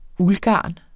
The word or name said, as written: uldgarn